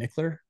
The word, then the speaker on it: Michler: And then I'm most active on Instagram, and that's at Ryan Michler. And my last name is spelled M-I-C-H-L-E-R.